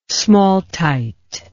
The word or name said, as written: smaltite